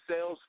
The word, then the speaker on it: Sayles: T is T-R-A-V-E-S-T-Y, numbers 86 at Twitter. Travis Sales Facebook. My last name is spelled S-A-Y-L-E-S. Some people spell that wrong, too, so I got to correct them. So that's S-A-Y-L-E-S, Travis Sales on Facebook.